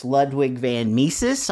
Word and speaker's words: Ludwig von Mises: I think we probably would agree that it's Ludwig von Mises.